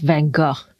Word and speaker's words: van Gogh: And while we were there, we were able to go to the Vincent van Gogh Museum, which, by the way, if you're actually Dutch, is pronounced van Gogh, which is what I learned while we were there.